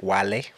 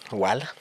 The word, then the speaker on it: Wale: Or for those who don't know how to pronounce it, Wale. Wale.